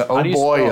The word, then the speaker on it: Oboyo: You would see Oboyo and everything. And I guess the lead singer wanted to call it that for, for some reason. – Oboyo. How do you spell that? – O-B-O-Y-O.